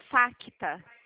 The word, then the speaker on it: Facta: Digita, por gentileza, primeiro o Facta. F de faca. Facta. O C é mudo.